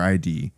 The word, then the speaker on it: id: But man, games is it pronounced id or ID? – I just call it id.